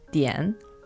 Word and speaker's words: 点: In Chinese, this is pronounced dian, and in English, this is pronounced dot.